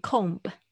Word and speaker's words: comb: 不要念成 comb。今天谁作业交，然后念 comb，我就要打屁股。就代表你们没有就是听我的任何的音频，没有上课。因为这 MB 的组合通常 b 都是不发音的。